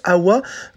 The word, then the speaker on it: Awa: En fait, le « Ah, what », ce n'est pas en anglais, la chanteuse le prononce Awa dans la langue Lingala.